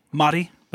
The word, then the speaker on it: Madi: I'm Madi. I do... – What? – Madi. That's my name. – How do you spell it? – M-A-D-I.